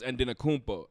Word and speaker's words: Antetokounmpo: which I don't want to butcher it because I've practiced Antetokounmpo, but it's Adenokumbo, if I recall correctly. – The Nigerian way. – Right. The T's are pronounced D's, and the MP is a B sound. So it's Adenokumbo.